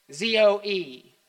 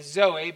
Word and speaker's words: zoe: The Greek word is zoe, Z O E. Some would call it, say zoe, but it's pronounced zoe.